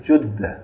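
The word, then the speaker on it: Jeddah: Now, people call it that. Some people call it Jedda. A lot of the Saudis call it Jedda. And other people call it Jedda. And the real proper pronunciation is Judda.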